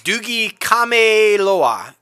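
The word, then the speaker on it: Doogie Kamealoha: Doogie Kameloa.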